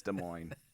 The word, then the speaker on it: Des Moines: I'm like, I know it looks like Des Moines, but it's Des Moines. Just. – Oh, the S is silent.